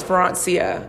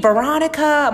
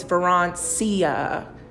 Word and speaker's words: Verancia: They'd be like, you mean Veronica? I'm like, no, it's Verancia.